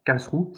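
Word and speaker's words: Karlsruhe: J'ai fait euh, alors Kalsrou si je le prononce bien. C'est ça. Bien. Et bon, ça, c'est voilà, c'était très bien, même si le stade était en travaux.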